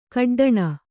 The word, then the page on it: ಕಡ್ಡಣ: kaḍḍaṇa